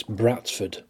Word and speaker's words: Bradford: It's Bratford. B-R-A-T-F-U-D. That's how the town name is pronounced by locals, and thus jokingly spelt, even by the excellent Bradford fanzine The City Gent.